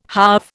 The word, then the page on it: halve: Indian